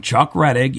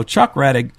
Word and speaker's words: Rettig: Chuck Redding, you know, with an N. It's like, I mean, come on, guys. I know we don't hear much from Chuck. – Right. – But get his name right.